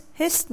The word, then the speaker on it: his: Các bạn đừng đọc là his, his nha, không phải là âm sờ đâu. Âm cuối của nó là âm z, his.